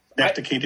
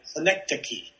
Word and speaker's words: Synecdoche: And he had that movie out that was spelled like Synodoki or... But do you know what I'm talking about? – Synecdoche, New York. – Yes, Synecdoche, New York, right? Is that Synecdoche. – Synecdoche, New York. And I pronounced it wrong.